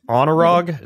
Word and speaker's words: Anurag: His name, and I hope I don't butcher your name, Dr. Singh, because I got the last name, S-I-N-G-H, but is it Anurag? Is that how you pronounce your first name? – Yeah, you got it correct.